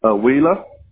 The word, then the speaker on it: Wheeler: Wheeler. – How do you spell that? – W-H-E-E-L-E-R. W-H-E-E-L-E-R.